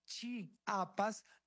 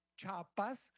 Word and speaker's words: Chiapas: Hoy hace 202 años, Chiapas, se pronuncia Chiapas, no Chapas, porque a los chiapanecos no les gusta que le digan Chapas.